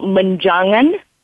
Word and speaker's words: Menjangan: So, am I saying that right, Manjana? – It's called Manjangan. M-E-N-J-A-N-G-A-N.